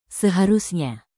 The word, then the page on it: seharusnya: indonesian